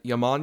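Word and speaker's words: Jamón: And then for my good, I had Yaman Yaman, which is J-A-M-O-N, is how that's spelled, and Parallel Mothers.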